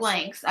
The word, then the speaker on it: Lynx: It's Lynx. I was so confused when you kept on saying Linux. I'm like, what is a Linux? – Okay, it's a Lynx.